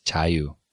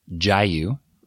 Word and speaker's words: Jaiyu: Now we chose Jaiyu because it's uh, it, actually, if I say it in English pronunciation, Jayu, right? Mm-hmm. The voiced J sound. We uh, we spell it J A I Y U, and we wanted a name that would be pronounced virtually the same in Korean and English.